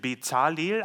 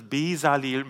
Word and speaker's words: Bezalel: Ja, wir würden vielleicht zuerst lesen Bezalel, aber wir haben uns im Vorfeld des Gottesdienstes tatsächlich auf die Aussprache geeinigt. Ne, wir finden, dass das Bezalel ein bisschen sanfter, ein bisschen leichter so auch von den Lippen geht, um das dann auszusprechen.